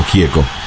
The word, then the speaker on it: Gieco: A pesar de que su apellido es de origen italiano, en Argentina se pronuncia simplemente como Gieco.